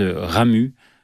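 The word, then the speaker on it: Ramuz: C'est l'auteur suisse Ramu, avec un Z à la fin, mais les Suisses ne prononcent pas le Z, ouais.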